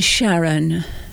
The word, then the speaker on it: Charon: And Charon.